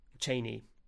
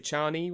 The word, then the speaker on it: Chani: I always pronounced it Chani when I read the book, and then apparently it's Chani when you. When you listen to the audiobook or you watch the movies.